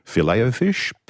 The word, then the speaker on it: Filet-O-Fish: This cheeky text asks, how do you pronounce fillet au fish? Well, it is French. Filet-O-Fish.